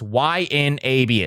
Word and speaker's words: YNAB: I use Y, and people always say, how do you pronounce that? It's YNAB. It stands for You Need a Budget.